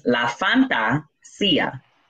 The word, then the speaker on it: La Fantasía: But I was like, no, I'm Latina. You're going to say it right. So I now, that's why I have it. It's La Fantasía in Spanish. It's fantasy, but in Spanish.